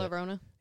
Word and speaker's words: La Llorona: Because I saw the Curse of Ya Llorona, or however you pronounce it. – La Llorona.